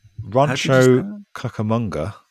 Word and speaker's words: Rancho Cucamonga: Rancho Cucamonga.